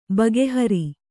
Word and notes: ಭಾಗಹಾರ: bhāgahāra

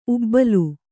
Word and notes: ಊಬಲು: ūbalu

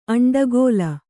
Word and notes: ಅಂಡಗೋಲ: Kannada

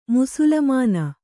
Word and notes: ಮುಸುಲುಮಾನ: musulumāna

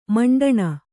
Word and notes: ಮಂಡಣ: maṇḍaṇa

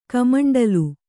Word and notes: ಕಮಂಡಲ: kamaṇḍala

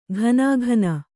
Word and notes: ಘನಾಘನ: ghanāghana

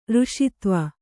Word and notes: ರಸತ್ವ: Kannada

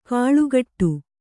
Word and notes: ಕಳೆಗೊಡು: Kannada

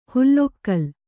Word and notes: ಹುಲ್ಲೊಕ್ಕಲ್: hullokkal

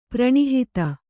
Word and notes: ಪ್ರಣಿಹಿತ: kannada

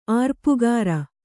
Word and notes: ಆರ್ಪುಗಾರ: Kannada

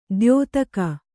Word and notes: ದ್ಯೋತಕ: dyōtaka

